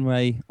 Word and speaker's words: Rae: Yeah, I'm at Nathan Ray on Twitter. – Cool. – So you can find me there. My name is spelled R-A-E, so at Nathan Ray.